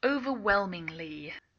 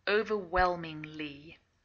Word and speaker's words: overwhelmingly: Overwhelmingly. Here it's used as an adjective, but of course it comes from the verb to overwhelm. And we don't usually pronounce the H. It's a silent H.